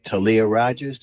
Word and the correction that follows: Tally Rogers: Did I say it right? – No, Tally Rogers.